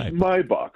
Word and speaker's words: Maybach: Now, Dave, now, first of all, now, if you're going to get into a $160,000 to $200,000 car, we must give it the proper German pronunciation. – Did I do it wrong again? – It's Maybach. – What's it is Maybach, my friend. – It's like my buck.